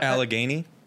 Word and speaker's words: Allegheny: North Allegheny? Allegheny? – Sure. Out of Pennsylvania. – A-L-L-E-G-H-E-N-Y. – Out of a Pennsylvanian high school. – Yeah. – Allegheny? – Allegheny in Wexford, Pennsylvania.